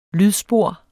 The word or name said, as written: lydspor